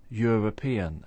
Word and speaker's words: European: But it doesn't begin with a vowel sound. It begins European, and the y is a consonant sound.